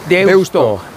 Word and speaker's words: Deusto: Deusto. ¿Qué pasa? ¿Qué haces con Deusto? – Es Deusto, porque se escribe con U final.